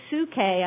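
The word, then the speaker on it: psuche (ψυχή): However, and let me just tell you the roots of that too, come from the Greek word, which is pronounced in the Greek psuche, I believe, but we call it psyche.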